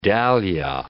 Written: dahlia

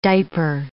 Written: diaper

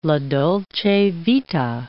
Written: la dolce vita

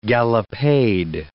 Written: gallopade

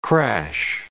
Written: crash

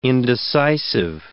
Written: indecisive

